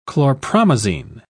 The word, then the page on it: chlorpromazine: British English